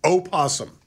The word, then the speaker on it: opossum: It's opossum. – Opossum. – That's correct. There is a possum, but what we have in the United States are opossums. – Yeah. – And the O is pronounced.